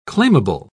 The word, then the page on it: claimable: British English